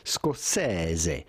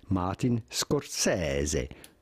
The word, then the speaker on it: Scorsese: In Italian, the typical Italian pronunciation would be Scorsese. Scorsese. So not Scorsese, but rather Scorsese. Martin Scorsese. Martin Scorsese. But it'd be fine to say Martin Scorsese. I would personally say Martin Scorsese rather than Martin Scorsese.